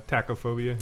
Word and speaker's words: tachophobia: That, is that how, am I pronouncing it correctly? Uh, tachophobia. – Yeah.